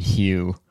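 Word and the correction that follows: Hough: Oh no. It's pronounced Huff.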